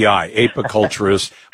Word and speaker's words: apiculturist: Is it apiculturist? – Apiculturist is the honeybee specialist. – But not correct. Ape like monkey, but API, apiculturist.